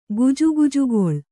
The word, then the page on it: ಗುಜುಗುಜುಗೊಳ್: kannada